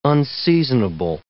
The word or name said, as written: unseasonable